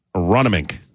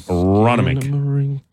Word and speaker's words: Aronimink: Guest hotline from Aronimink. Aronimink. That's how you say it. Aronimink.